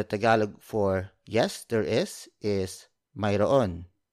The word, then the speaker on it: Mayroon: The Tagalog way of saying yes, there is Mayroon. It's spelled as M-A-Y-R-O-O-N. Mayroon. Mayroon. Mayroon. You can contract that word into Mayroon. That's spelled as M-A-Y-R-O-apostrophe-N. Or you could pronounce it even more casually like Meron. Meron. That's spelled as M-E-R-O-N. But for this lesson, we're going old school.